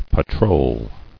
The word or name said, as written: Patrolled